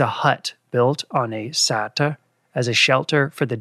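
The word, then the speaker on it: seter: Sissel seems to pronounce it satra, and it's S-E-T-E-R in English, at least.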